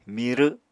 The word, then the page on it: mirw: Apatani